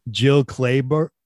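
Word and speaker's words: Jill Clayburgh: Jill Klaber, is that how you say her name? – Yeah.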